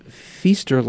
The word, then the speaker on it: Feaster: Because Megan said you pronounce Feaster like Easter.